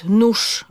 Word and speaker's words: nóż: Mówimy na przykład nóż, mimo że piszemy ż,